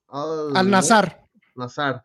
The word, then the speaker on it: Al-Nassr: Al-Nassr.